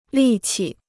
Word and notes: 力气: lì qi

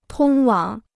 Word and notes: 通往: tōng wǎng

